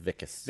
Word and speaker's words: Wickes: It was with a W, but it's pronounced Vickis.